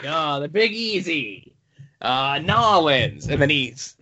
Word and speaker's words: New Orleans: It's pronounced Nolens.